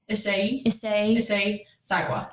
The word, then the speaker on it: Issei Sagawa: Issei Sagawa. Did I say it how I was supposed to? – Yeah, I think it's Issei Sagawa.